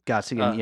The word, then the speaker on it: Gottsagen: And one of the things that I do know is that somehow they, I guess, had a relationship with Zach Gottsagen. I think that's how you say it. – Gottsagen, yeah.